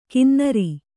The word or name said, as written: ಗೊನ್ನೂರು